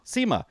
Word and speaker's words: Sima: Sima. And so kind of pronounced like Zima, but with an S, right? – Right, yeah.